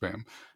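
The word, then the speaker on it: Boehm: So Boka Restaurant Group was founded over 20 years ago by Kevin Bam, and he's the Bo, even though it's pronounced Bam, and Rob Katz.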